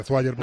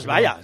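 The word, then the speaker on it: Zwayer: Sí, seguramente se pronuncia, se pronuncia vaya pero vamos a llamarle Zweier. – No, no, Svaya, Svaya.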